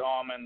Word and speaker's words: Aumann: Well, again, the name is Lee Aumann. The last name is spelled A-U-M-A-N.